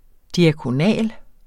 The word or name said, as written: diakonal